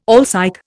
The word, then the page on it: alsike: Indian